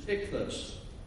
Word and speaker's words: ichthus: The Greek, the word for fish is ichthus. I-C-H-T-H-U-S.